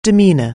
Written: demeanour

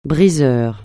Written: briseur

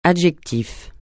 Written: adjectif

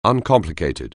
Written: uncomplicated

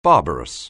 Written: barbarous

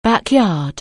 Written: back yard

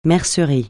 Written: mercerie

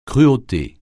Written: cruauté

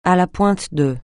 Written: à la pointe de

